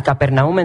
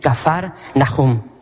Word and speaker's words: Kafar Nahum: Y hermanos, se nos nombra esta ciudad, la ciudad de Capernaum en sí, la pronunciamos de esa manera, pero es Kafar, así con F, Kafar Nahum. Cuando vas a Israel, como que se te pega un poco el acento de los judíos, escupen mucho. Y es la forma correcta de pronunciar este nombre, Kafar Nahum.